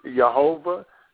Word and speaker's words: Jehovah: So that word Yud Hey Vav Hey is also pronounced Jehovah. Some people pronounce it Yahweh.